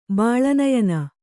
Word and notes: ಬಾಳನಯನ: Kannada

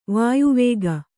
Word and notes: ವಾಯುವೇಗಿ: vāyu vēgi

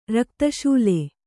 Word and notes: ರಕ್ತಶೂಲೆ: Kannada

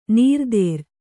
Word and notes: ನೀರ್ದೇರ್: nīrdēr

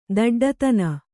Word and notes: ದೊಡ್ಡಿತನ: doḍḍitana

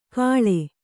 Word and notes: ಕಳೆ: kaḷe

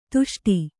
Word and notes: ದಷ್ಟ: Kannada

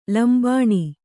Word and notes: ಲಂಬನ: lambana

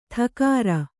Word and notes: ಥಕಾರ: thakāra

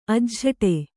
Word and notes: ಅಜ್ಝಟೆ: ajjhaṭe

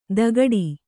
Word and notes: ದಗಡಿ: dagaḍi

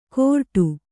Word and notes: ಕೋರ್ಟು: kōrṭu

